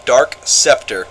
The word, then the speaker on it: Dark Scepter: And they, every time we played together, they would always pronounce my name as Dark Spectre, and they'd do it in this stupid hick accent, Dark Spectre over there, just mispronouncing the hell out of it,